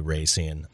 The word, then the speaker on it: Racine: I was going to ask you, how do you say it? – I personally say Racine. It's 50-50 down the board. Racine, Racine.